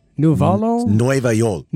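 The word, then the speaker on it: Nuevayol: I think this is pronounced Nuevo Yol. – Nueva Yol.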